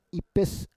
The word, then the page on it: ipes: Roon